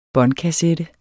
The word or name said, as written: båndkassette